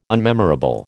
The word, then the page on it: unmemorable: American English